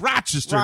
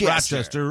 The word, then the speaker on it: Rochester: That's the way you pronounce it, too. It's Rochester, not Rochester. Rochester.